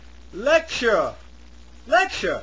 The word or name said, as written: lecture